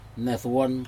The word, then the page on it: nedoun: Aneityum